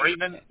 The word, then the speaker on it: Bremen: B-R-E-M-E. – Yeah, Bremen. – Yeah, yeah, Bremen.